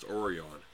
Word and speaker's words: Orion: If you're from Southeast Louisiana, you've probably heard it pronounced Orion. – I mean, I've heard it pronounced Orion. Maybe because that's I read so much. That's just me. Sorry. I'm that guy. But, you know, I can understand Orion.